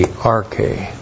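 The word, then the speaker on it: arche (ἀρχή): Apa indicates origin or source and is correctly translated from the arche, from the beginning. Arche. A-R-C-H, long E, arche.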